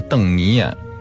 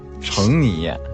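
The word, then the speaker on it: 澄泥砚: 端砚、陶和砚和邓泥砚并称为这个中国四大名砚。就这里边有一个那个，就是大家都容易读成那个成泥砚。 – 哦，对，其实那字念邓啊。 – 三点水加一个攀登的登。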